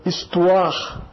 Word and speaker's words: histoire: Histoire. Histoire. É um H mudo. Não é pronunciado.